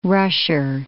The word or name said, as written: rusher